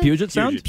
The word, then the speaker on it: Puget: There is a 2004 research paper published in Biological Conservation that described how a female orca in the Puget Sound, is that how you pronounce it? – Puget Sound?